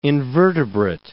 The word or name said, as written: invertebrate